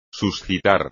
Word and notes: suscitar: espagnol

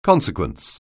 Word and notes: consequence: anglais

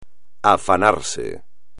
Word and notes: afanarse: espagnol

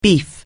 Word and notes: beef up: anglais

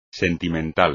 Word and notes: sentimental: espagnol

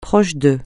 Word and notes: proche de: francais

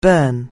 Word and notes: burn: anglais

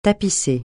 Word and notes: tapisser: francais

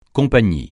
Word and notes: compagnie: francais

